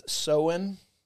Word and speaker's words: Samhain: But by and large, the majority of folks seem to kind of agree it originated somewhere with the ancient Celtic festival of Samhain. I don't know if I'm saying that right. But yeah. – Samhain. – There's the Druid pronunciation is Soen.